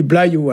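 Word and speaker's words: bloazioù: Alors ici, on prononce pas ce Z-là, hein, on dit blaiou.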